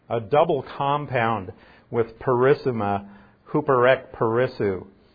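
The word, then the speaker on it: Huperek perissou (ὑπερεκπερισσοῦ): Huperek perissou is how it's pronounced in Greek. Huperek perissou.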